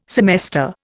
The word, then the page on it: semester: Indian